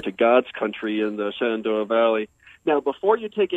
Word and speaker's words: Shenandoah: Now, I hope I say it correctly, in the Shenandoah Valley? – That's correct, yes.